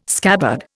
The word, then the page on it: scabbard: Indian